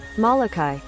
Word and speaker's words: Molokai: Uh, and that is in uh, that's a county on the Hawaiian island of I believe that's that's Molokai, right? – Molokai.